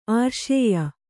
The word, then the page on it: ಆರ್ಷೇಯ: ārṣēya